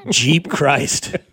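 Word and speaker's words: Geep Chryst: But it's spelled with a G, right? – Yeah. Yeah. G-E-E-P and then C-H-R-Y-S-T. – Yeah. – I mean, Jeep Christ.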